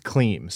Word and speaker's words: Klim: Okay, so I have seen the Klim Reaper, but it's pronounced Kleem.